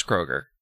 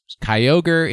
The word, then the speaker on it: Kyogre: Look, the alternate pronunciation of Kyogre is Kroger. We all know this. This has been well established on this program. – Yes. Kyogre is the correct answer.